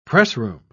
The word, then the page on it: pressroom: British English